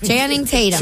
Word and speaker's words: Channing Tatum: Channing Tatum.